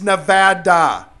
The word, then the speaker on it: Nevada: It's Nevada. Say it right. I know. It's semantic. No, that's the right way to say it. Ask someone who's from Nevada. They'll tell you.